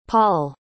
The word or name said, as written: paul